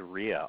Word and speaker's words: Ria: Hey, we're going to start with Rhea, R-I-A. Hi, Rhea, welcome.